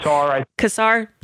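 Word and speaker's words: Casar: is it Kassar? Is that how you say his name? Kassar? – That works. – Okay. – Kassar.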